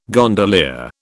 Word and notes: gondolier: British English

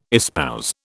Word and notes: espouse: British English

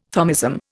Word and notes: Thomism: Indian

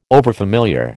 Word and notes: overfamiliar: American English

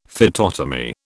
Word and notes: phytotomy: British English

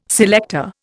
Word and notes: selector: Indian

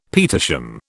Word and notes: petersham: British English

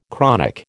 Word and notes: chronic: American English

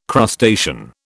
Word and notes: crustacean: British English

